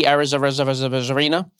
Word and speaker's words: Arozarena: Randy Arozarena.